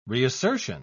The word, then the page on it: reassertion: British English